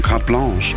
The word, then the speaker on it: Carte Blanche: Es wird Carte Blanche geschrieben und ich war so, wie spricht man das aus? Ja, es sind Franzosen, Carte Blanche.